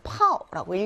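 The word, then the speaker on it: 파오라고 (泡): 얘는 파오라고 읽고요. 절이다라는 뜻입니다.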